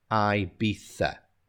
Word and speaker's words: Ibiza: So some people have different pronunciations. My Spanish is not so perfect, but the English usually pronounce it Ibiza. Ibiza. Yeah, even though it's spelled I-B-I-Z-A, we have that TH sound at the end, Ibiza.